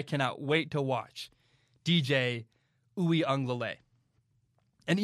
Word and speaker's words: Uiagalelei: I'm pretty sure you say it, DJ Uiagalelei.